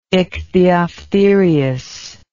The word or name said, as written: ichthyophthirius